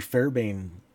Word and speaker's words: Fairbairn: Yeah, and that goes back again to the Major Fairbairn, there's an extra syllable in it.